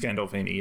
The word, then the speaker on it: Gandolfini: Am I saying his name right? James Gandolfini, yes.